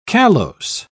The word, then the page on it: callose: British English